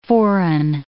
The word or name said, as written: foreign